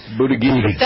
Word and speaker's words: Buttigieg: Peter, the aforementioned Buttigieg. Thank you. Why, how do you get that out of this? That isn't how it's spelled. – Buttigieg. – That's how it's pronounced.